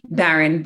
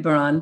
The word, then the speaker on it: Baran: But if you have a chance to go sit in front of your computer and and go to uh, Elisa Baran, did I pronounce that correctly? – Baron Baron.